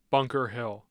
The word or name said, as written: bunker hill